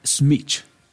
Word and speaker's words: Schmidt: Pronúncielo bien. Fernanda Schmidt. – Schmidt. – Eso, así.